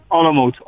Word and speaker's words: Olomouc: Senti, intanto correggimi, si dice Sigma Olomouc, vero, come pronuncia del club? – Sì, Olomouc.